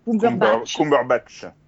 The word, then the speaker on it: Cumberbatch: Donc on a euh, Ben Whishaw, David Suchet, Patrick Stewart, Jeremy Irons, Lambert Wilson, j'arrive jamais à prononcer son nom, Benedict Cumberbatch. – Cumberbatch.